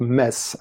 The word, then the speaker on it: Metz: We pronounce it mess, actually. – Okay. Thanks for the correction. – The spelling is M-E-T-Z, and most French people pronounce it the German way, which is Metz. But in Metz, we are the only people pronouncing it Metz, and we are very proud of that because of the Latin origin of the name, Messina.